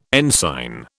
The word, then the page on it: ensign: British English